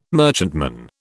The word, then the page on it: merchantman: British English